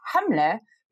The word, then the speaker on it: Hamle: which is it's pronounced Hamle because the seven is actually representative of a letter in the Arabic alphabet.